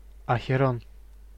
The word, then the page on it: Ахерон: /əxʲɪˈron/